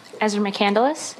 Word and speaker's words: Ezra McCandless: My name is Ezra McCandless, E-M-E-Z-R-A-M-C-C-A-N-D-L-E-S-S.